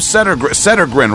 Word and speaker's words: Settergren: Rob Settergren, right? – You got it.